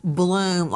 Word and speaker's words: Blum: Again, joining me in All-Star Panel to make sense of what we know right now, but straight out to Howard Bloom, spelled B-L-U-M, pronounced Bloom like the flower, author of a hit book, When the Night Comes Falling, a Requiem for the Idaho Student Murders.